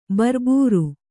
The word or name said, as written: ಬರ್ಬೂರು